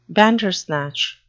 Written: bandersnatch

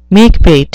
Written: makebate

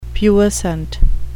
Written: puissant

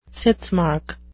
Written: sitzmark

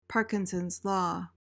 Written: parkinsons law

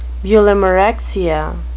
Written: bulimarexia

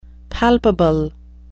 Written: palpable

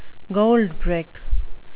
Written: goldbrick

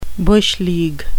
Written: bush league

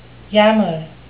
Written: yammer

